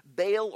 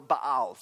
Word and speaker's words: Baal: In Numbers chapter 25, you see the people, some of the Israelites, beginning to worship Baal or Baal. Some people pronounce it differently.